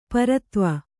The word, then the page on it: ಪ್ರಿಥುವಿ: prithuvi